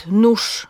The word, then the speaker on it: nóż: Mówimy na przykład nóż, mimo że piszemy ż, wóz, mimo że piszemy z, nie widzę gwiazd, mimo że tam jest z i d, obserwuję bieg, a nie g, piekę schab.